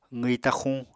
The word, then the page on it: qwi taxuñ: apatani